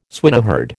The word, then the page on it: swineherd: American English